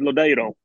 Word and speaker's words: Lodeiro: For years, people were calling him Lodeiro. And he had done an audio pronunciation where he said himself, Nicolas Lodeiro. But actually, his preference is Lodeiro. So moving forward, he is absolutely going to be Nicolas Lodeiro.